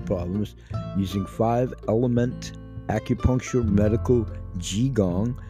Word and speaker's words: qigong: You know that we talk a lot about qigong, workouts for geriatrics in our series there.